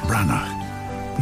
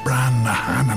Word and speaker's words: Branagh: And Kenneth Branagh, not, as I've heard many of you pronounce it, Kenneth Branaghan.